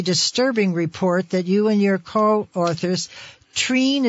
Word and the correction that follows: Trina: is that how you pronounce? – Trina. – Trina. Trina Beck.